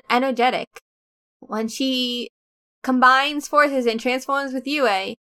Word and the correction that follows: Yui: I don't think the I is meant to be pronounced that way. Yui?